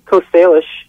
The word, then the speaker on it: Coast Salish: Coast Salish. – Coast Salish? – Territory.